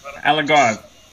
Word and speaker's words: Algarve: which is the capital of the Algarve region. Do I pronounce that correct? – Yeah, the Algarve.